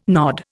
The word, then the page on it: nod: Indian